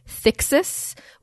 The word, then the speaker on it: thix: the fact that it's like thix, like thick, that's how I would probably remember it.